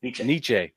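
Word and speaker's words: Necciai: Nietzsche? Yeah. Is that how you pronounce his name? – Nietzsche. – Okay. Yeah. Ron Nietzsche.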